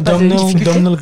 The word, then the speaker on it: Domhnall: Non, c'est Domno Gleeson, cet acteur-là. – Ah. Domno Domhnall.